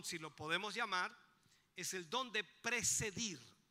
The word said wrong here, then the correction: presidir: o ¿cómo se dice? Presidir, esa es la palabra correcta, presidir.